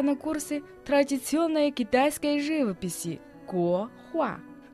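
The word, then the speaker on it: 国画: Гохуа. Го – это страна, государство. Произносится во втором тоне. А хуа означает картина, живописи в третьем тоне.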